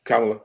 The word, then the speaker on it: Kamala: And am I saying her name right? Kamala? – Kamala, yeah.